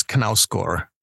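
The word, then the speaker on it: Knausgård: The publisher told me it was Knauskor, so that's what we went with.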